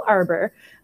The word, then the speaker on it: Arbour: On Instagram, it's letter I, letter B, Nicole Arbour. And I spell it A-R-B-O-U-R.